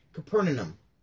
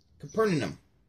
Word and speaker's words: Capernaum: Capernaum. Capernic. No wonder they didn't take a knee. Capernaum.